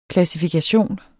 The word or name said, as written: klassifikation